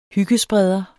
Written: hyggespreder

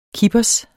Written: kippers

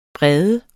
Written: brede

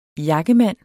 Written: jakkemand

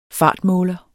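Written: FARTMÅLER

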